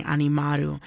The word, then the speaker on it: Animaru: Just inspired, she says, by the Japanese pronunciation of the word animal. In Japanese, this is the album that we're hearing, Animaru.